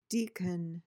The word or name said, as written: deacon